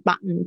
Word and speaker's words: button: 这个也也是夹在两个元音之间，button button button 不不念 button 不念 button，就是会有时候 t 都不见了，它跟后面的 n 有关系。 button 啊，就常会念成 button，就是 t 就听不到了。